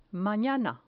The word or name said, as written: manana